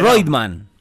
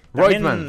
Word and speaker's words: Reutemann: Reutemann. Es la pronunciación de eh, original. Reutemann Reutemann.